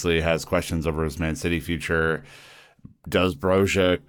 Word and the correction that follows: Broja: come back? Or Broja, I should say, is the right pronunciation.